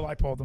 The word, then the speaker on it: Leipold: Leipold, Kansas? – No. – I love that one. – Lance Leipold. – Or Leipold, I'm sorry. – Yeah. – I said it wrong.